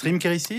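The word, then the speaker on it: Reem Kherici: On termine avec les comédies de ce 26 avril, Stéphane, et tout d'abord, jour J de, comment on prononce, Rim Kérissi ? – Absolument.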